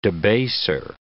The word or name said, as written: debaser